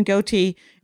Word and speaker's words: Gootee: Kevin Gootee, and that's how you pronounce his name, Kevin Gootee, as he said, the thing I will never grow.